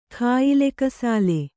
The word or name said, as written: ಕಾಯಿಲೆಕಸಾಲೆ